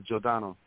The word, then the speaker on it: Giordano: And how do you pronounce Jeff's last name, Jeff M. Giordano? – Jeff Giordano? – Giordano, okay. Jeff Giordano.